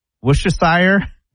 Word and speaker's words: Worcestershire: So you don't say the R? You don't say Worcestershire? – No.